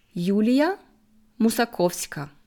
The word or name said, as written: Yulia Musakowska